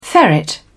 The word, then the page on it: ferret: British English